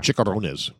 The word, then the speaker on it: chicharrones: Chicharrones.